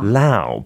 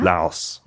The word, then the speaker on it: Laos: hearing it pronounced without the S. – 우와. Lao. – But I think these days more people, yeah. Laos. Okay.